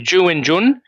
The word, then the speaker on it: Zhu Wenjun: Zhu Wenjun defeated Tan Zongyi by the narrowest possible margin, if it didn't go to tiebreaks.